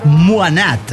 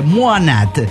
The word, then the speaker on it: Mohnad: En la clase hay un egipcio llamado Mohnad, que se pronuncia algo así como Muanat. – Se llama muy egipcio. – Sí, se llama muy egipcio. Un egipcio, Jonathan, no da. Así que, Muanat. Con cierta fuerza en la N.